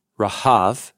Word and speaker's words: Rahab: So Rahab the dragon is from Resh He Beit, Rahav, so it's spelled like it sounds in English, which is from a verb, Rahav, to like a raging storm.